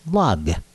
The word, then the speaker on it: Lag: Since Hebrew letters also have numeric significance, the 33rd day of the counting of the Omer comes out as Lamed Gimel, which is therefore pronounced Lag, you know, Lag BaOmer, the 33rd day of the Omer.